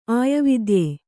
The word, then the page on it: ಆಯವಿದ್ಯೆ: kannada